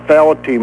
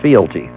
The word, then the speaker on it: fealty: but I wanted to know exactly what fealty meant. Adherence to something which one is bound by a pledge or duty. – The word is fealty. Fealty.